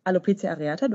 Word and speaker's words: Alopecia areata: Ich zum Thema Alopecia areata, du hast es gut ausgesprochen. Im Deutschen sagt man dazu auch Alopezie. Ich selber sage Alopecia, genau.